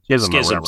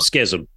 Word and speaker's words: Schism: That whatever that's, you know, Chisholm. – Schism.